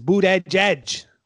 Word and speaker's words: Buttigieg: They say his name is Buttigieg.